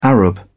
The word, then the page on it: arab: British English